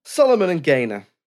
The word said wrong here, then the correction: Gaynor: Solomon and Gaynor.